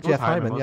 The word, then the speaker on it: Hyman: Jeff Hyman, yeah.